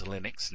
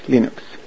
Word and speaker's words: Linux: And in case you're wondering, it is Linux, not Linux, not Linux, not any other pronunciation. I have it by a great authority, Linus Torvalds himself. – Hello, this is Linus Torvalds, and I pronounce Linux as Linux.